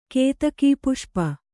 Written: ಕೇತಕೀಪುಷ್ಪ

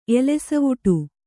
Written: ಎಲೆಸವುಟು

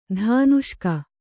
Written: ಧಾನುಷ್ಕ

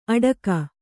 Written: ಅಡಕ

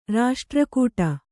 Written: ರಾಷ್ಟ್ರಕೂಟ

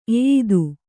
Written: ಎಯ್ದು

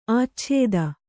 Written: ಆಚ್ಛೇದ

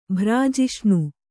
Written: ಭ್ರಾಜಿಷ್ಣು

